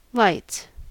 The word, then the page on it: lights: American English